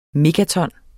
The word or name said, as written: megaton